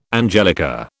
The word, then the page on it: angelica: British English